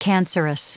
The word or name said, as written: cancerous